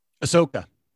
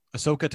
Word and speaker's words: Ahsoka: Um, how do you pronounce that? Ahsoka? – Ahsoka. Ahsoka Tano. – Ahsoka. – Ahsoka. Yeah.